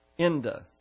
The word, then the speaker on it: ENDA: It's pronounced ENDA. ENDA, that's the acrostic for it,